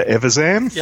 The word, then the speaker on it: Evazan: It has Walrus Man and Dr. Evazan.